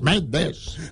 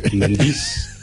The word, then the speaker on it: Méndez: Son dos sílabas, Méndez.